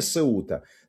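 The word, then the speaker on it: Ceuta: Потому что логичное название Сеута.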